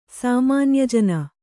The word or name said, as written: ಸಾಮಾನ್ಯಜನ